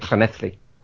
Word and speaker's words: Llanelli: Double L in Welsh is a cl. So, yeah, so there's a town in Wales that's double L-A-N-E-L-L-I. It's phonetically it's Llanelli, but they say it Llanelli.